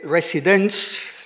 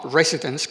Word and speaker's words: residence: Y señalado con colores las rimas que, como van a apreciar ustedes, mm, son algo imperfectas todavía, porque está claro que Residence, es como por lo visto se pronunciaba en la primera mitad del siglo XVI, no Residence como ahora, y Pretense, y luego Negligence y Reverence, eso está claro, ahí está siguiendo la pauta del soneto italiano.